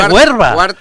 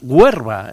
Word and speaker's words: Huerva: El Cuarte de Huerva. No es que esté pronunciándolo mal, sino que es que se llama Huerva, la localidad de Zaragoza.